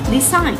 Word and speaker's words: design: Design. Como ves, no se pronuncia la G.